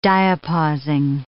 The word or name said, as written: diapausing